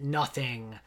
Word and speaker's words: nothing: The sort of highfalutin literary approach to this always observes that nothing would have been pronounced similar to noting, which in Shakespearean era, dear listeners, was taken to mean gossip, rumor, conjecture, and gossip plays a big role in the play.